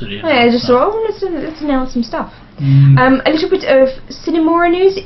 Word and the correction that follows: Cinemuru: I still don't know if I've said that right. – Cinemuru.